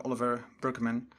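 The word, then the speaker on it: Oliver Burkeman: We hebben vandaag het boek 4000 weken van Olivier Berkman, of hoe je het ook mag uitspreken, Oliver Berkman. Het is een Engelsman.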